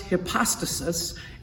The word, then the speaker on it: hypostasis: In Greek, it's pronounced hypostasis, and it has the meaning to hold up under.